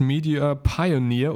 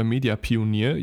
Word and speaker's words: Media Pioneer: Ähm, er hat jetzt eine, eine, ein neues Medienunternehmen gegründet, das heißt Media Pioneer oder Media Pionier, je nachdem, wie man es aussprechen möchte.